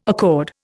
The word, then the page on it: accord: Indian